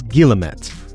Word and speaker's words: guillemet: The word of the day is guillemette, or guillemet in French. It is spelled G-U-I-L-L-E-M-E-T.